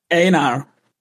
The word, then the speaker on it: Einar: Hello, Ejnar. – Einar. – Einar, thank you. Yeah. – Thank you. – Sometimes on these international servers, I spell it with a Y. It makes it simpler, but it's Swedish.